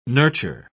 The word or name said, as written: nurture